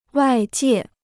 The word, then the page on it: 外界: wài jiè